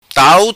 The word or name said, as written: Tau